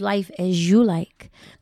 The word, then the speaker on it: LifeAzulike: That's LifeAzulike. Mm-hmm. So it's Life As You Like. That's how you say it.